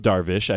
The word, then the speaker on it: Darvish: Well, in full transparency, I screwed up Darvish. I didn't get the H sound at the end of that one.